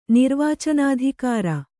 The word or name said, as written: ನಿರ್ವಾಚನಾಧಿಕಾರಿ